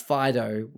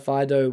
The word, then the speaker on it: Fidough: but also we got introduced to the best thing, which is Fido, which is spelled F-I-D-O-U-G-H, which is bread roll dog, and it's the best.